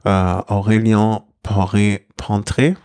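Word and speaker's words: Aurélien Paret-Peintre: Aurélien Paré-Pontré. Habe ich das richtig ausgesprochen? Oder zumindest im Ansatz richtig? – Ja, sehr gut.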